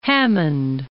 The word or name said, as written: Hammond